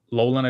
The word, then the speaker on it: Loughlin: So did you ever hear the story of Lori Loughlin, I believe it's pronounced, paying for her kids to get into college in the U.S.?